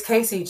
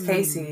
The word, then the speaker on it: Kes: I always say Kay or Keys. – Yeah, it's Casey. Do you know what? It's the spelling. Spelling gets us every time, but it's Casey.